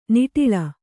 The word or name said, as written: ನಿಟಲ